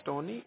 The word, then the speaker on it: Tony: My name is Tony. It's spelled like T-O-N-Y.